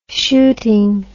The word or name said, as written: shooting